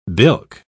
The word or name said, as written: bilk